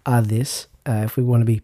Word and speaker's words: Hades: Well, he was actually going to the underworld, as we might call it in English, also called Sheol in Hebrew and Hades in Greek. Although my more astute listeners of Greek will know that it's pronounced Hades.